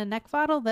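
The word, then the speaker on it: Neckvatal: You can also find me on LinkedIn just by using my name, Brenda Neckvottal, that's spelled N-E-C-K, like the thing you want to choke, V as in Victor, A-T-A-L.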